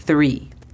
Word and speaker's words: three: La pronunciación fonética sería T-H-R-I. Hacemos el sonido de la TH colocando nuestra lengua entre los dientes y soplamos. Three. Four. Cuatro. La pronunciación fonética es F-U-O-R. Four. Five. 5. La pronunciación fonética es F-A-I-V.